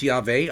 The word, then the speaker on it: Yahweh: Adonai, Lord, if you recall, is a cover word, because in the ancient Hebrew, in the original Hebrew, is the original name of God, Yud-Heh, Vav-Heh, that I pronounce Yahweh, others pronounce it maybe in a different way.